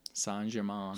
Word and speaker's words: Saint Germain: French or German? French pronunciation. There you go. Saint Germain. Saint Germain.